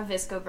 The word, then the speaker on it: VSCO: A VSCO girl. Thank you for making that sound because when it's spelled out, I'm like, what the hell is Yes.